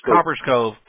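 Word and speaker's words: Copperas Cove: How do you pronounce that city? Copperas? – Copper's Cove. – Good. There you go.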